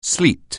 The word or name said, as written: sleet